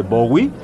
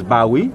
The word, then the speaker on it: Bowie: No, Graf, contrario a lo que tú digas, no se dice Bowie, es Bowie.